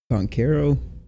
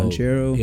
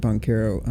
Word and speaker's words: Banchero: I mean, Paolo, I think it's pronounced Banchero. – Oh, Banchero. Yo, Banchero. Yeah. – Uh, an announcers pronouncing it Boncaro. I don't think that's right, but uh, that guy's, and I think he's the number one pick now.